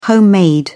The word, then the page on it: homemade: British English